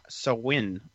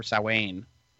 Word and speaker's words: Samhain: I think it's pronounced Sa- Sawin or Sawain. Yeah. – Yeah. It's, it's spelled not spelled S- like that at all. No, it's spelled S A M H A I N.